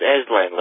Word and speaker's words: Aslan: It's Aslan, like the lion. – Can you spell that? – It's the A-S-L-A-N.